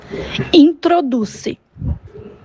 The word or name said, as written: Introduce